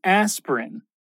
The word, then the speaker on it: aspirin: So, if you're looking at this word aspirin written out, you might be a little confused about the pronunciation. But remember, it's pretty simple. Just two syllables. Aspirin. Aspirin. Now, Bob is a good friend and gives James some advice.